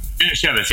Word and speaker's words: Manischewitz: And you top it off with a splash of Manischewitz, which is a uh, it's actually a um, kosher wine. Oh, yeah. kosher wine. A kosher wine. You mean Manischewitz? – Manischewitz, yeah, thank you.